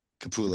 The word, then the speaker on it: Capullo: When I was downstairs and Dave Capello or Capullo, however you say his name. – Capullo.